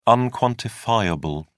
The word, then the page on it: unquantifiable: British English